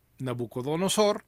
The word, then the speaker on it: Nabucodonosor: y es como el rey Nabucodonosor, ahora sí lo dije bien, se dio cuenta de que el dios de Daniel era el dios auténtico.